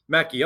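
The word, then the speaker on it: Mecci: You're going to be working with master hypnotist Assad, and if I'm mispronouncing his last name, it's Mechie or Mechie? – It's Mechie.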